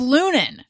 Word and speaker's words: Loehnen: L-O-E-H-N-E-N. – I know. – It's pronounced Lunan.